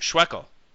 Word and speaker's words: Schweckel: And I think you also mentioned, or at least when we're talking offline, about John Schwetzel, I believe that's pronounced? – Schweckel. – Like Schweckel. – Like Freckle.